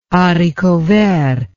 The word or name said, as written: haricot vert